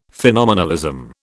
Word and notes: phenomenalism: British English

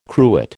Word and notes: cruet: American English

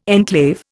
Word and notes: enclave: Indian

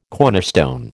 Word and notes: cornerstone: American English

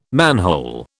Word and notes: manhole: British English

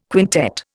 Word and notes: quintet: Indian

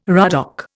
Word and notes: ruddock: Indian

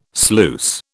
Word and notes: sluice: British English

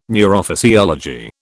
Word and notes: neurophysiology: British English

